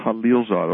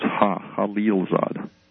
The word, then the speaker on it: Khalilzad: And then, is it Khalilzad? – His name is pronounced Khalilzad, although it's spelled K-H, but yeah. It's pronounced Khalilzad.